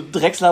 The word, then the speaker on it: Drexler: Jetzt hast du Drexler aber auch schön betont, also Betonung auf Dreck.